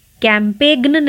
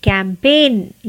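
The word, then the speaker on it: campaign: कैम्पेग्न नहीं कैंपेन ये सारे शब्द हम जानते हैं हमने सुने भी हैं बट इनका स्पेलिंग अलग है और प्रोनाउंसिएशन में साइलेंट लेटर नहीं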